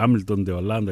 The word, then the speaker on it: Hamilton de Holanda: Hamilton de Holanda γράφεται, Hamilton de Holanda η προφορά.